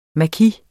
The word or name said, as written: maki